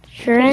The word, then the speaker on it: Sheeran: Sheeran? – Good job, you said his name right.